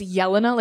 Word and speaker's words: Jelena: I just like to say it's Yelena, like you're yelling, yeah.